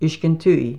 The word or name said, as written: UISKENTUIE